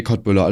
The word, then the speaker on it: Köttbullar: Ich glaube, wir sagen da alle immer Köttbullar zu, aber ich glaube, im Original wird das Siertbullar oder so, wird das ausgesprochen, meine ich, ne? – Nee, Köttbullar, alles gut, Digga.